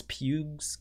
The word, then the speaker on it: Pugh: We also did find out today that Florence Pugh's character, is that how you say her name? – It is Pugh.